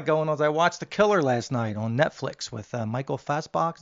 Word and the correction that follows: Fassbender: Did I say his name? Fassbacher, Fassbender, whatever. – Fassbender? Yeah.